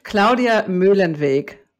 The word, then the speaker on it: Claudia Muehlenweg: It's like a vague German thing. Tell me how to say it exactly right, Claudia. – First of all, I'm so glad to be here. Claudia Muehlenweg. – Muehlenweg. So I almost had it.